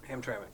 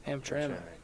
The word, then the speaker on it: Hamtramck: Hamtramck. – There we go. – Hamtramck.